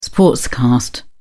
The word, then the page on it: sportscast: British English